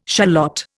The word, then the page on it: shallot: Indian